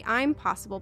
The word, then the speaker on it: I'm Possible: I'm Possible Podcast. It's spelt like impossible, but there's a big emphasis on the I'm, and it focuses on conversations surrounding identity in the workplace and how we can build a life that's defined by them.